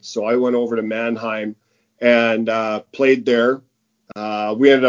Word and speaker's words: Mannheim: But you did stay over there after, even with Monningham. There you go, Brooks. I got the name right. Yeah. you had the lockout there, but you did. – In where? – It's Mannheim. – Oh, Jesus. Oh, I almost got it.